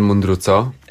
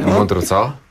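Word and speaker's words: Mundruczó: Tak, Kornel Mundruco. – Dobrze, wymawia pan nazwisko węgierskie. – Mundruco.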